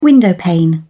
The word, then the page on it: windowpane: British English